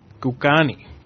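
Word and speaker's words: Kokanee: What do you think? How do you pronounce that? K-O-K-A-N-N-E. Cocani? – Cocani.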